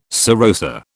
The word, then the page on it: serosa: British English